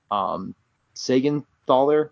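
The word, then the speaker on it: Siegenthaler: And then you have Graves, Sagenthaler, right? Did I say it? – Yep, that's how you say it.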